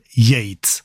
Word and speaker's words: Yeats: ¿Y esto cómo se pronuncia? ¿Y esto? Yeats. Pues hemos buscado en ese canal de pronunciación de YouTube que es enriquecedor absolutamente, y bueno, es William Butler Yeats, así que así lo vamos a llamar.